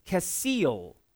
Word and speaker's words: kesil: So the way you pronounce that Hebrew word, the first one, is kesil. Kesil.